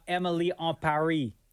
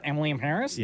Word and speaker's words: Emily in Paris: First of all, thank you for saying it properly. – That is how it's supposed to be said. – Were we not told when the show? – Yes, we were. – Shortly after the show debuted, we were told how to say it properly. Yeah. Not Emily in Paris. – Yes, it's Emily en Paris.